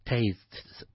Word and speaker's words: tastes: It's difficult, STS at the end. I know. Right, so what you have to do is, again, separate the syllable. Okay, mm-hmm. So tastes, then okay. You put like a TS at the end.